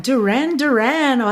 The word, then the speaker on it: Duran Duran: E aí não só essa música não estava, como Duran Duran, or Duran Duran, olha, já fica a dica de pronúncia aí em inglês,